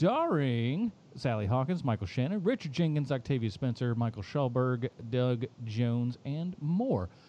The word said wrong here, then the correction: Michael Stuhlbarg: How do you not know how to say his name still? It's Michael Stuhlbarg.